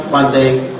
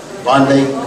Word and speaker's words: Vande: It's not onde guru shri. Guru means like a cow. So you have to learn how to pronounce it properly. You say, Vande Guru Sri Chananda.